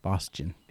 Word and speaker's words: Bostian: It's like, it's German, first of all, so that's what makes it difficult. – Okay. – It's Bostian. – Bostian. – Yeah. You say it like it's spelled Bostian,